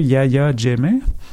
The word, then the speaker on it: Yahya Jammeh: Il y a de la pression très forte actuellement pour traduire en justice l'ancien président, corrige ma prononciation s'il le faut, Yahya Jammeh.